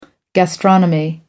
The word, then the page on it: gastronomy: American English